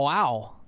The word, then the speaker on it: OAO: It's spelled O-A-O. Oh, wow. And it sounds like Christopher Walken saying wow.